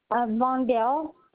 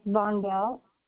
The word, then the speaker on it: Vondall: Vondell. – Spell that for me. – V-O-N-D-A-L-L, Anthony Roxanne Vondell. – V-O-N-D-A-L-E? – No, V-O-N-D-A-L-L.